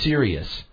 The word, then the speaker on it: Cereus: It's called a Sirius. Now, the name alone, you know, sparks my interest, but it's spelled C-E-R-E-U-S.